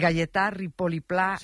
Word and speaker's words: Gaietà Ripoll i Pla: de Gaietà Ripoll i Pla, un mestre y jurepensador que tenía la seua escola a l'Horta de Russafa.